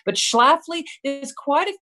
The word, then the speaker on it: Schlafly: But when you have control, how much control did you have over this, the Schlafly project? – You said it right.